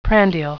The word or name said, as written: prandial